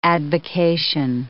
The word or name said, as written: advocation